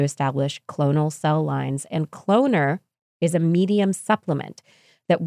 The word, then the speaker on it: Cloner: Cloner. As cloner rhymes with donor, from Stem Cell Technologies, made to increase the cloning efficiency of human pluripotent stem cells, HPSCs.